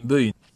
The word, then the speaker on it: bugün: Bugün kelimesi böğün değil mi? – Böğün. – Böğün olarak telaffuz ediliyor.